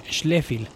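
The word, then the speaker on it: Schläfli: es S-H-L-L-A, diéresis F y L, Schleffel, sería como su pronunciación en alemán.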